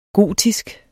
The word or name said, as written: gotisk